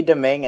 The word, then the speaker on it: Domingue: Louis Dominique, Vancouver Canucks acquire for Zane McIntyre. I definitely didn't say that right. – It's Louis Domingue and Zane McIntyre.